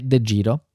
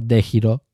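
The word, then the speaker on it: DEGIRO: Per esempio, uno che ho guardato um, di recente e che ho usato tanti anni fa è De Giro o De Giro, perché è un'azienda olandese, quindi si pronuncerà in, quella maniera più o meno, um, con cui mi sono trovato bene in passato.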